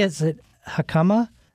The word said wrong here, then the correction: jicama: No, and the way you pronounce it's spelled J-I-C-A-M-A. – Like jicama.